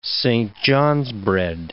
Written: Saint John's bread

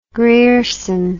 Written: grierson

